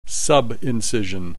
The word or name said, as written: subincision